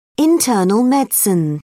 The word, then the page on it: internal medicine: British English